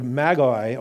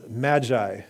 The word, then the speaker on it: magoi: And so the Greek word translated wise men is the word magoi, or magi is how you hear it pronounced.